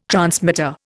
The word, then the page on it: transmitter: Indian